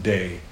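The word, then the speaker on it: day (δεῖ): It's just three Greek letters. Delta, Epsilon, Iota. We pronounce it day. It comes in several different forms, but that's the primary form, day.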